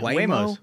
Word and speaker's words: Waymo: Fellas, we've got a clip here that is is from the San Francisco Standard, first of all, which is a Waymo? Is that the right way to say it? – Yeah, Waymos.